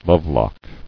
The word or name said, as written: Lovelock